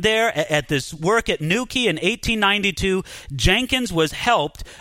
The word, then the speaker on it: New Quay: Don't think that's spelled, you know, N-E-W-K-E-Y. No, New Quay is spelled N-E-W, and then in a separate word, Q-U-A-Y. But it's pronounced New Quay.